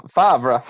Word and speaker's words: Favre: Like, Brett Favre, that's not how you spell Favre. That's Favre. – Yeah, it's Favre.